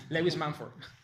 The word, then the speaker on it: Lewis Mumford: Lewis Montfort. – Ah, oui. – Oui, la prononciation, c'est toujours un peu difficile. Je l'ai fait à la française pour vous, Mumford, mais non, c'est Lewis Mumford.